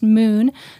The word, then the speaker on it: MXXN: It's MXXN, pronounced Moon. So don't just Google Moon.